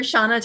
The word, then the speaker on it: Shanna: You can look for me on IMDb under Shanna Toft.